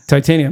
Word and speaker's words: Titania: Tatiana. Ultron. – Titania. – My bad. Tatiana. Yeah, that's spelled different. Ultron. I read the whole comic and called her Tatiana.